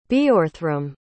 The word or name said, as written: Beorhthram